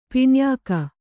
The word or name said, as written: ಪಿಣ್ಯಾಕ